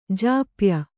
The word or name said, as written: ಜಪ್ಯ